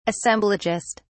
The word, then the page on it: assemblagist: British English